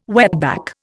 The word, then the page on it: wetback: Indian